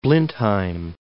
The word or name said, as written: Blindheim